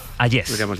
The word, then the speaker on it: Hayez: Hayez.